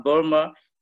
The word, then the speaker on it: Burma: Entonces Bama a Burma y escribe B-U-R-M-A Burma, antes eh, se llamaba Burma.